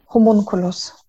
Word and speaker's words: Homunculus: Und jetzt eben dein Prosa-Debüt erschienen im, und jetzt musst du mir vielleicht nochmal mit der Aussprache helfen, Homunculus Verlag? – Homunculus.